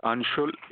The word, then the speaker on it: Anshul: It's Anshul, A-N-S-H-U-L.